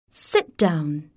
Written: Sit down!

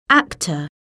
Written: actor

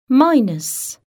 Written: minus

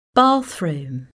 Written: bathroom

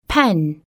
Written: pen

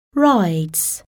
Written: rides

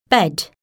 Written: bed

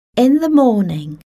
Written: in the morning

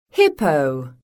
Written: hippo

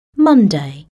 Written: Monday

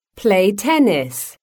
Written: play tennis

